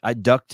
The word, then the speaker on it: duct: And what's the other word that I don't pronounce? Duct tape, D-U-C-K. I don't pronounce it. It's D-U-C-T, duct. I don't do duct tape. – Duct tape? – I think that's what it is, right? D-U-C-T, duct.